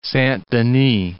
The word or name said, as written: Saint-Denis